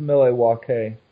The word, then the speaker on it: Milwaukee: It's pronounced Milwaukee.